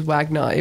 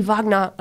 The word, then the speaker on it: Wagner: He pronounces his own name as Wagner, even though every time I mention his name, my mum's like, it's actually Wagner. I'm like, Mum, I know. But he just chooses to mispronounce it.